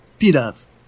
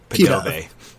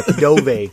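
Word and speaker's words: Pidove: And it's pronounced P-Dove. – It's Padoov. – Nah. – Padove. – Yeah, Padove. It's like Tarjay.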